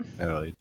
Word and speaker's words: Adelaide: Adelaide? I think. – Adelaide.